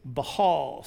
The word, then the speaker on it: Baal: I just was looking it up to make sure I really knew, and I forgot that there was a silent H, not silent, a very quiet H in the middle. It's Baal. So they worshipped Baal.